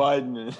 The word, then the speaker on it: Weidman: You keep calling him Wheelman. – Weidman. How do you, say it again? Say it for me. – Weidman. – Weidman. All right. He should spell it W-I-D or something.